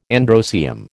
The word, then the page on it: androecium: American English